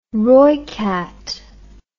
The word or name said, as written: rooikat